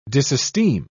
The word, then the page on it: disesteem: British English